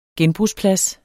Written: genbrugsplads

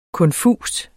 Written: konfus

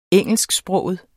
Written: engelsksproget

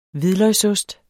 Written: hvidløgsost

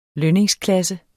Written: lønningsklasse

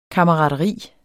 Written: kammerateri

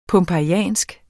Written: pompejansk

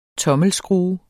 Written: tommelskrue

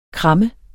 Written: kram

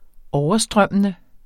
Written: overstrømmende

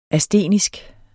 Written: astenisk

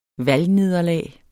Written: valgnederlag

